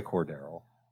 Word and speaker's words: Cordarrelle: So if you think he's going to keep making these explosive plays, then by all means, start Cordarrelle, enjoy Cordarrelle. Am I saying his name right? – Yes.